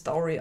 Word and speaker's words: Dyfri: Man schreibt nämlich D-Y-F-R-I und die behaupten, man spricht das Dory aus.